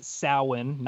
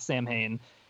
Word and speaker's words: Samhain: First of all, it's pronounced Samhain, not Samhain.